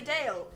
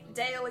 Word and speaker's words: Dael: They can find me on Twitter at Daily Dale. The first word is spelt normally. Dale is spelt D-A-E-L.